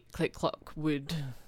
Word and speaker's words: Click Clock Wood: And I'm in the Click Clock Wood.